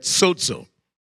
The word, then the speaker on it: sozo: To be saved comes from the Greek word sozo. Sozo, S-O-Z-O, or some spell it S-O-D-Z-O.